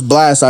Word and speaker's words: Blxst: So basically, so this that was Blast out of Cali. Salute to Blast. If you don't know how to spell it, it's BLXST.